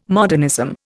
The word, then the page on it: modernism: Indian